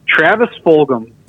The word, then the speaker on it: Fulgham: How do you even say his name, dude? – Travis Fulgham.